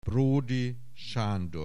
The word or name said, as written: Bródy Sándor